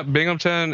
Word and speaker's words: Binghamton: Yeah, Binghamton is pretty, it's what they call upstate New York.